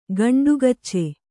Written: ಗಂಟುಕಾಚಿ

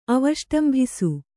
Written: ಅವಷ್ಟಂಭಿಸು

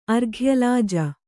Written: ಅರ್ಘ್ಯಲಾಜ

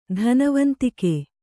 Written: ಧನವಂತಿಕೆ

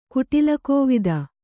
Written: ಕುಟಿಲಕೋವಿದ

